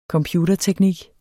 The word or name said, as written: computerteknik